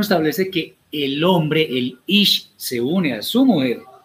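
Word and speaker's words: ish (איש): de la palabra hebrea ish, que se escribe Aleph, Yod, Shin, Ish.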